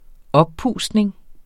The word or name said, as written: oppustning